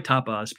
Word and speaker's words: Tapas: It's going to be a tough battle for Tapas. – Like how you pronounce it. You pronounce it like a Latin American entree.